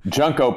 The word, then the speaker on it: Junko: So, Junko Partner is pronounced that way because it's it's about, about the junk.